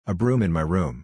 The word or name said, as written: a broom in my room